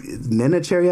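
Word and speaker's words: Neneh Cherry: Yeah, I think Nina Cherry, I believe is how it's pronounced.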